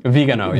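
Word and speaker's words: Viganò: Now the New York Times, a former newspaper, reported this today as Vigano, is that how you pronounce it? – Vigano, yeah.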